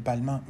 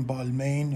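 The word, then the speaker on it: Balmain: البته این برند توی انگلستان هم بیشتر همون چیزی تلفظ میشه که توی خود فرانسه است و اکثر انگلیسی هم به اون بلما میگن، ولی در آمریکا همون بالمین همونطوری که نوشته میشه خونده میشه.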